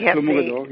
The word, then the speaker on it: Clos Mogador: But we're talking about the Clos Mogador. Is that how you pronounce it? – Yes, Clos Mogador, yes, well.